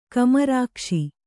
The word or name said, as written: ಕಮರಕಸ